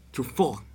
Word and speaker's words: Truffaut: Pronuncialo bien, ¿viste? Oh. Fahrenheit 451, que salió en 1966. Sí, ¿qué se puede decir? El director de esta obra fue François Truffaut. Así, es francés, lo tenés que decir bien.